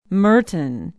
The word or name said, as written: merton